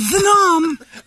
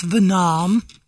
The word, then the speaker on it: Venom: And like that, all three of Venom. You're saying that wrong. It's Venom.